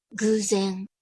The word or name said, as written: guzen